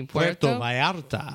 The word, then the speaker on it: Puerto Vallarta: Puerto Vallarta.